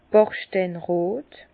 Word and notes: bachsteinrot: Bas-Rhin; Herrlisheim

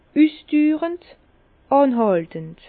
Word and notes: üsdürend: Bas-Rhin; Herrlisheim

